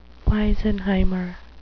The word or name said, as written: wisenheimer